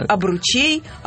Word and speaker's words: обручей: Ударение по-прежнему на «о», но в косвенных падежах «о ужас». Ударение переходит на окончание «обручей», «обручам», «обручами», «об обручах». Ну, это я тебе скажу правильно, потому что говорить об обручах, наверное, все-таки сложнее, чем об обручах.